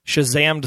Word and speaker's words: Shazammed: In case you didn't know it, The Hollywood Reporter actually tracks what are the most topped Shazammed songs on TV. – Say it right. – Shazammed! Yeah, that's right.